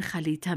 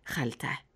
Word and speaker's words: خریطه: در بخشی از نواحی افغانستان مثل هرات به آن خلیطه می‌گویند و در بعضی نواحی مثل کابل خلطه.